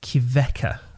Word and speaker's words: Kveikur: So I would really, really like you to check out Kvecka. Now, I'm probably pronouncing that wrong. – Kvecka. – It's I think it's like Kveikur. That sounds Icelandic-y, doesn't it? – Yeah.